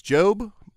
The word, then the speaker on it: Job: They are pronounced differently when the first letter is capitalized. Job becomes Job, polish becomes Polish, and herb becomes herb.